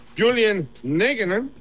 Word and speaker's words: Julian Nagelsmann: Habló también el director técnico de la selección nacional más joven de toda la justa deportiva, Julian Nagelsmann. Ojalá que no haya devastado. ¿Así se pronuncia, pueblo hermano? – Está perfecto, sí. Amigo, está perfecto.